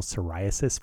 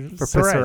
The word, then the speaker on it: Psoriasis: So the best tagline I've heard of a company recently, I'm working on a website for the National Psoriasis Foundation. Okay. To which their tagline is, the P is silent, but we are not.